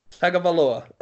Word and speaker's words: Tagovailoa: Then up next is Tua Tagovailoa. Did I pronounce that correctly? – Tua Tagovailoa. – Tagovailoa. – Ah, yeah, man.